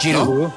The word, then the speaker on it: Giroud: Vea la pronunciación de este. Oliver Giroud. ¿Qué tal? – Giroud. – ¿Giroud? – Sí, sí, o sea, Giroud es Giroud. – Giroud. Es que en francés, bien dicho, es así. Giroud.